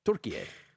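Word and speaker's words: Türkiye: It's the Turkish pronunciation of Turkey. Turkiye.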